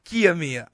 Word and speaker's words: Kiyomiya: Katamiya. – So close. – Oh, my goodness. – Kiyomiya.